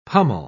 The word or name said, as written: pummel